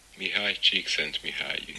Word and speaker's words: Mihai Csikszentmihalyi: Mihai Csikszentmihalyi.